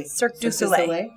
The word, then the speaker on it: Cirque du Soleil: They park them over there by Cirque du Soleil. Is that, did I say that correctly? – Cirque du Soleil.